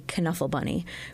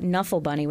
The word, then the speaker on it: Knuffle: I have been told by those who know that it's not The Knuffle Bunny, because it's spelled K-N-U. – Right. – F-F-L-E. It's not Knuffle Bunny, which is what we would think, but it is Knuffle Bunny.